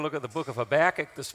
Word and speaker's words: Habakkuk: We've been talking about the different ways you can pronounce the book Habakkuk, and they pronounce it in some parts of America, Habakkuk.